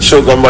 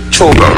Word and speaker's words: CHOGM: I say Shogun, but it's Chogum, right? – Chogum, C-H-O-G-M.